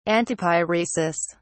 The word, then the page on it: antipyresis: British English